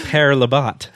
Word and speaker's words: Père Labat: that it's pronounced Père Labatt and not Père Labatt. Like I've been saying. – You noticed I just changed it right there. – I did, yeah. So it is Père Labatt.